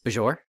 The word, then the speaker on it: Bajor: I'll also point out that Maritza pronounces Bajor the same way that Michael Burnham does, which is Bajor. This is when people are still, it wasn't quite canonized the proper way of saying Bajor, but they'd come around by the time you get to season 7 of Deep Space Nine.